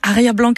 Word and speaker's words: Arie Blanc: C'est Arie Blanc si vous le prononcez en français.